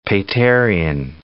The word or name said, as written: Paterian